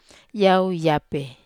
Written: ape

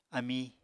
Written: amí

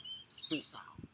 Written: fesau

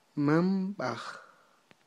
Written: manb'aj

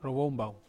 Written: ro wombau

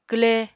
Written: kwle

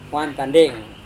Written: wantandeng

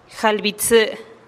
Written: jalih bi tsï

